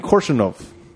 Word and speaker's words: Korshunov: Gary Oldman's Ivan Kursovanov. – What? You want me to spell it for you? – Hold on. Korshunov? Ivan Korshunov. Ah.